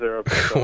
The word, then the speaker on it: Aeropostale: Is that how it's pronounced? I thought it was Aeropostale. – It is Aeropostale.